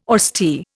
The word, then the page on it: austere: Indian